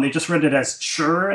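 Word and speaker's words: chur (吃): And then the odd thing by is that so in the pinyin spelling, chi is now ci, but the word for eat is spelled chi. But it's also for complicated reason in Beijing dialect is pronounced chur for eat.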